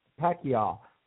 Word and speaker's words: Pacquiao: The thing about it is Pacquiao.